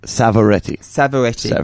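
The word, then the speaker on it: Savoretti: The first time, Jack Sovietti. Did I say it right? – Yeah, you did. Close. It's a tough one. – Jack Sovietti. – Savaretti. – Got it. – Yeah. People mix it up with Pavarotti.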